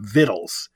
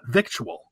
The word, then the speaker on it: victual: And I used to watch a show many years ago, the Beverly Hillbillies, and Granny would always have vittles. It has like a Southern intonation to it. And that's the correct pronunciation. The C-T that came in much later, and it's still pronounced vittle, not victual.